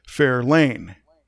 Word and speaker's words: Fairlane: But I'm pretty sure that the Fairlane was named after Fairlane. And you'll notice my different inflection. I'm accenting a different syllable as I say Fairlane.